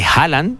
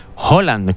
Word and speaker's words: Haaland: Y te quería, me viene a la mente pues, un vídeo que vi de, oye, que estamos pronunciando mal el nombre de este delantero de Noruega, de Haaland, ¿no? Que todo el mundo lo llama así, Haaland, pero que se pronuncia realmente Holland, como si fuese como el actor este británico, Tom Holland, pues exactamente igual.